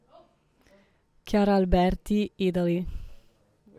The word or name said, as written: Chiara Alberti